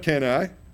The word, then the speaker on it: Cannae: If you'll notice, it's spelled C-A-N-N-A-E. It's right below the words Adriatic Sea on your map. Little town of Cannae.